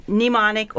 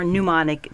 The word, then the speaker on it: mnemonic: Okay, but this does have mnemonic or mnemonic. Both of those are correct pronunciations.